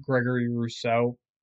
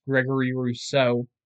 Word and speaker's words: Gregory Rousseau: Wow, I butchered that name. If I was the Jets, I want Gregory Rousseau. His name, even though it's difficult to say all at once, Gregory Rousseau.